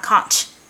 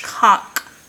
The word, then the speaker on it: conch: First of all, I just want everyone to know on YouTube at any podcast, it is not conch. It's conch. – I said that for a long time. – It's conch. If you're going to say something, say it properly.